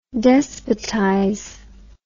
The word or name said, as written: despotize